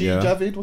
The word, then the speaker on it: Javid: Javid, what's his? Am I pronouncing name correctly? – Yeah, but he resigned and he didn't have any scandal attached to him as he resigned.